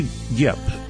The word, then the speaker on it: Diep: We also have with us Julie Diep, who, and I hope I said your last name correctly, it's spelled D-I-E-P, but you were saying in the break that in Vietnamese, the D is kind of pronounced more like a Y sound.